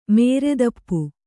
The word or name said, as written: ಮುರಿತಾಪ